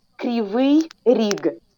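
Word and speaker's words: Kryvyi Rih: Krivi Ri, is that how you pronounce it? How do you say the name of the city that the dam is that got hit with rockets? – No, it's called Krivi Rig. – Oh, boy. I missed by a long shot.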